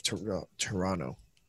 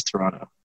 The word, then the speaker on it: Toronto: What is Toronto? – Yeah, what? Why don't we pronounce the second T? That's what I want to know. – Oh, Toronto.